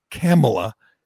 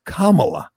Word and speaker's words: Kamala: And he warned, and he told everybody on Fox, you better get her name right, because it's not Kamala, it's Kamala, which...